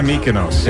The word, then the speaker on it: Mykonos: Is that how you say it? – Yeah, Mykonos.